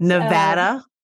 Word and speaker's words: Nevada: So can you go into more detail about some of the new protections that have been put in place in Nevada? – Sure, absolutely. So a tip for non-Nevadans, it's Nevada. I want you to be good on these streets. – Nevada? – Nevada, yes.